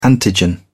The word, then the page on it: antigen: British English